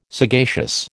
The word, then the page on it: sagacious: American English